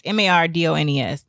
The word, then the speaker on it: Mardones: Number 11 is a song called Into the Night by Benny Mardones. I don't know how to say his last name. It's Mardones.